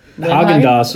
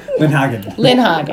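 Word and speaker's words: Lindhagen: Which is funny, that's one of those names where I won't let you butcher it, because I'm like, no, it's David Lynn-Haagen.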